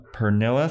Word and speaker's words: Pernilla: She says it's pronounced like vanilla with a P, but it's not spelled like vanilla. So I was like, I'll take your word. But much love from Denmark, from Pernilla.